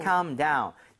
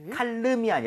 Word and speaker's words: calm: 칼름이 아니라 L 발음은 묵음입니다. So, 네. – calm down. 자, 참고로요.